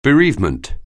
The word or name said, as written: bereavement